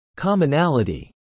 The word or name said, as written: commonality